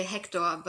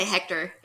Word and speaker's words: Hektor: Yeah, well, in German you would say Hector, but you can say Hector. It probably sounds weird if you try to pronounce it in a German way.